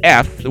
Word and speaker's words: EHPH: And the stimulating synth sensations of F, which is spelled E-H-P-H, but it's pronounced F.